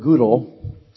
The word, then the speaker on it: Gödel: As some of you are aware, Kurt Gödel's, he's a German, so there's an umlaut over the O. So it's pronounced U. Kurt Gödel, his incompleteness theorems were in the news recently.